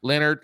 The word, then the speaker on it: Leonhard: Number one, I think, is Jim Leonhard. I think that's how you say his name. – Leonard. – Let's go with Leonard.